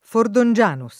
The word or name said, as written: Fordongianus